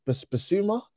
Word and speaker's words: Bissouma: Is it Bissouma, their midfielder? – Yeah, yeah, yeah.